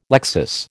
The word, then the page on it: lexis: American English